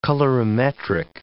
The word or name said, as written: colorimetric